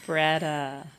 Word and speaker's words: BREADA: BREADA. Kind of like bread, food, with an A on the end.